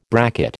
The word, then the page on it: bracket: American English